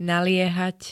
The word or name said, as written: naliehať